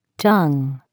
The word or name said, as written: tongue